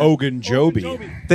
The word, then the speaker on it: Ogunjobi: Ogunjobi.